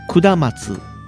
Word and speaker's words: 下松: っていうのがあって、これはあの、下の松と書いて、くだまつはい。って読むみたいで、カップ麺にもなってる。